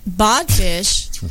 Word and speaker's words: Bodfish: Okay, so Bodfish right. – rhymes with codfish.